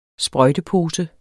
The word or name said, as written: sprøjtepose